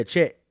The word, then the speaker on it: Chet: I knew is fucking Cheat or however you pronounce the name, Holmgren. – Oh yeah, Chet.